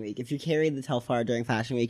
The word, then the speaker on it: Telfar: Did you see the TikTok of the Asian girl who had her Telfar bag stolen? – Mm-mm.